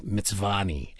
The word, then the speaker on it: Mtsvane: But the white wine grapes like Mitsvani, M-T-S-V-A-N-E, these varietal names that start with four consonants, you know, Riccazzatelli, yeah.